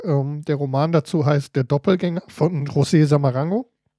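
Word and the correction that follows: Saramago: Äh, Sarah Mango, doch, Sarah Mango heißt der Typ, glaube ich.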